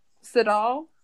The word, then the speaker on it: Siddall: I've never heard anyone say her name, but her last name is spelled S I D D A L L. Siddall? – Siddall. I think that sounds about right.